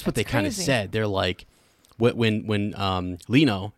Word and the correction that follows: Kino: But Kino. I can remember his name because Kino is the German word for cinema or theater, like...